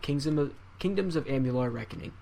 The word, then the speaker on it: Amalur: So, kingdoms of uh, how do we say this? Alamar. Amular. – Amular.